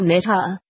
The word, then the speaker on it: Nazaret: Han udtalte Nazaret på en slik måde, at jeg ikke forstod ordet, før jeg spildte af båndet efterpå og lyttede nøje. Det lød som nathæret, sagt hurtigt.